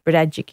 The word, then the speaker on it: Berejik: Berejik is how you pronounce it.